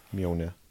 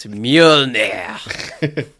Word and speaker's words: Mjolnir: Mjolnir. Yeah. – Correct. But you didn't pronounce it correctly. It's Mjolnir.